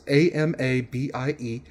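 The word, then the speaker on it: Amabie: And it is the mythical Amabii, as I believe how it's pronounced. It's Amabii.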